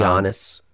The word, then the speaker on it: Giannis: Janice? is that how you pronounce his brother's name? – I think um, Giannis.